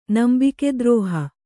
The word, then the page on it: ನಂಬಿಕೆದ್ರೋಹಿ: nambike drōhi